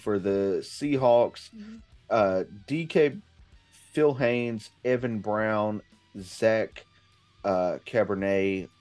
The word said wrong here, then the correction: Charbonnet: are all questionable. – Charbonnet.